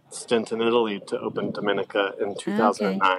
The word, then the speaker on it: Domenica: Dominica.